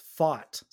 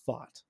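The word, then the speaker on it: Pfaadt: I just need to let everyone know that it's pronounced Fought.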